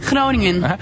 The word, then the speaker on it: Groningen: So, how do you say Groningen? – Groningen.